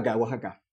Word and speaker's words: Oaxaca: Para entonces la X en México tiene todo tipo de sonidos. Entonces aquí es Oaxaca. – Ok. – Se puede pronunciar de muchas maneras.